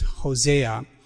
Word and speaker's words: Hosea: If you say his name using the Greek, it's Osi, and if you use the Hebrew, it's Hosea.